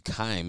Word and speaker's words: Keim: They also announced the general manager, Steve Keim.